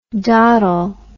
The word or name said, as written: dottel